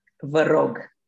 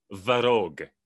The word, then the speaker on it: vă rog: Vă rog. – Vă rog. – Yes. – Vă rog. So you have to really roll your R's, do you? Like vă rog.